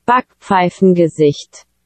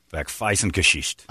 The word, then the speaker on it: Backpfeifengesicht: Backpfeifengesicht. – Backpfeifengesicht.